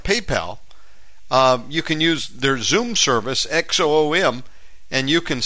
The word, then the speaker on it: Xoom: It's called Zoom, but spelled with an X. X-O-O-M.